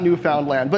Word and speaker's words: Newfoundland: Am I pronouncing that right, Deirdre? Newfoundland? That's what I was taught, but I'm American. You're Canadian. – Newfoundland. – You can tell me these things. Newfoundland? Okay, good.